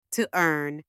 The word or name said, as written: to earn